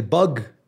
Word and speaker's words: bwg: Se escribe B-W-G. – Pero obviamente porque es Gales. – Pero se pronuncia bug.